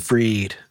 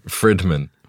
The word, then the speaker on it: Fridman: You know what I hate is that his name is spelled F-R-I-D, but he says Fried. Like that's, no, it's Fried. It's Frid. Fridman.